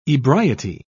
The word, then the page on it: ebriety: British English